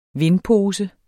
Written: VINDPOSE

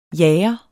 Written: jager

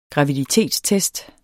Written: GRAVIDITETSTEST

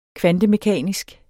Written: kvantemekanisk

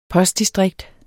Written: postdistrikt

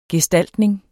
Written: gestaltning

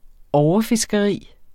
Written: overfiskeri

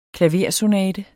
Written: klaversonate

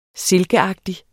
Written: silkeagtig

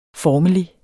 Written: formelig

